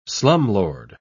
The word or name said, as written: slumlord